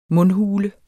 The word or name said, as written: mundhule